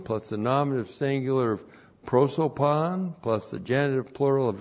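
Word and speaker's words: prosopon (πρόσωπον): Prosopon looks like that in the Greek, P-R-O-S, long O-P-O-N, prosopon.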